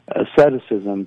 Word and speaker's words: asceticism: So I don't think it's just a question of, we're not monks, I mean, or what's called aesthetics, into aestheticism. – Right. – Not aesthetics in the sense of beauty, but asceticism, excuse me, I mispronounced that,